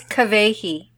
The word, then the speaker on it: Kawehi: And it's Kavehi. – Kavehi? – Yes, okay. Kavehi. Remember the W's are pronounced like these.